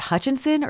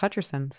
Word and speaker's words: Hutcherson: Is it Hutchinson or is it Hutcherson? – Yeah. – You know, turns out it's Hutcherson, so.